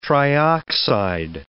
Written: trioxide

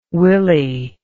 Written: Willie

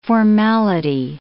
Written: formality

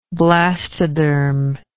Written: blastodermatic

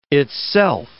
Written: itself

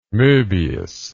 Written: mobius

